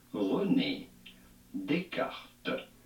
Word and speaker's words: Descartes: We would say it as René Descartes.